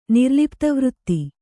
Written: ನಿರ್ಲಿಪ್ತವೃತ್ತಿ

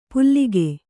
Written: ಪುಲ್ಲಿಕೆ

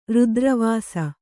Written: ರುದ್ರವಾಸ

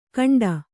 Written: ಕಂಡ

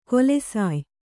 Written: ಕೊಲೆಸಾಯ್

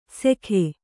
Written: ಸೆಕೆ

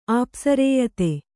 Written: ಆಪ್ಸರೇಯತೆ

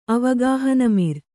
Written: ಅವಗಾಹನಮಿರ್

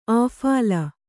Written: ಅಫಳ

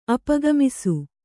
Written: ಅಪಗಮಿಸು

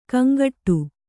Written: ಗೊಂಗಟೆ

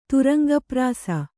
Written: ತುರಂಗಪ್ರಾಸ

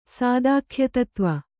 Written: ಸಾದಾಖ್ಯತತ್ವ

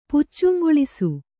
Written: ಪುಚ್ಚುಂಗೊಳಿಸು

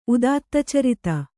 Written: ಉದಾತ್ತಚರಿತ

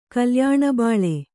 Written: ಕಲ್ಯಾಣಬಾಳೆ

